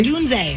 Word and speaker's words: Odunze: It's named after rookie Rome Odunze or Odunze? – Odunze. – Odunze, thank you, Odunze.